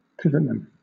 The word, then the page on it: pivotman: British English